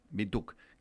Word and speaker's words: Windhoek: Vos vivís en una ciudad que se llama Windhoek, algo así. – Sí, Windhoek.